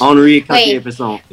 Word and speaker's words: Henri Cartier-Bresson: Henri Cartier-Bresson. I don't know how to say the French. – Henri Cartier-Bresson, yeah.